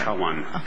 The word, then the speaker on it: Nijhawan: And you're citing Nijhawan? I'm not sure if I'm saying that correctly. Nijhawan.